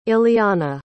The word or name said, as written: iliana